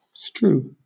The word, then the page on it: strew: British English